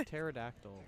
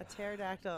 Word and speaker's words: pterodactyl: I want a pterodactyl. – A pterodactyl. – Okay, he wants to be a dinosaur. – So that your pee is silent? – Yes.